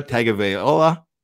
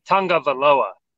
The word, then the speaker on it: Tagovailoa: I only know this because Tua himself pronounced it, it's not pronounced anything like it's spelled. It's actually Tua Tungavalova or Tungavaloa.